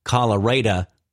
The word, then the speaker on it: Colorado: Tim Boyd, mayor of the West Texas town of Colorado City, pronounced Colorado, not like the state, turned to Facebook to showcase his heartless ignorance and spelling deficiencies in a post he acknowledged would hurt some feelings.